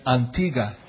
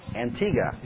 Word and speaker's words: Antigua: Is that Americans say Antigua, but they say Antigua. – Antigua. – Yes.